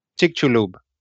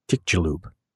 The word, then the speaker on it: Chicxulub: Chicxulub. – There you go. Chicxulub.